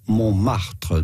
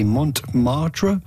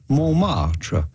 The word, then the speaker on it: Montmartre: Montmartre. The T in the middle is silent, so do not say Montmartre, but rather Montmartre.